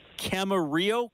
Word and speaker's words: Camarillo: Tell me about growing up in, and forgive me if I'm pronouncing it wrong, what was life like as a kid in Camarillo, California? Is that how you say it? – That is perfect. That is great pronunciation of that.